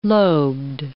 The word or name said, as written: lobed